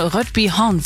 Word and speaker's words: Rødbyhavn: Der Fehmarn dann mit dem dänischen Rødbyhavn verbinden soll.